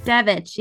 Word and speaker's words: Devich: Devich, yeah.